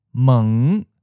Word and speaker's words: mng: spelled H-N-G, meaning far, distant. Teng, spelled T-H-N-G, meaning sugar. Meng, spelled M-N-G, door. You might have noticed that in the last two words, there's a short U sound inserted before the N sound. So it becomes ng. This is common to make it easier to pronounce.